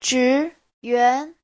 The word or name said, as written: zhi2yuan2